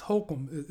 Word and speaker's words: Holcomb: H-O-L-C-O-M-B, yeah. – Because there's a place outside of Lynchburg called Holcomb Rock, but it's Holcomb. It's spelled H-O-L-C-O-M-B. – Right. – But it's called Holcomb.